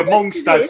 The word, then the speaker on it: Mongstad: So it's M-O-N-G-S-T-A-D, Mongstad. – Oh, that's I've had a Mongstad. – I've had a couple. – Yeah, I've had a Mongstad. – Mongstad sounds well IKEA to me.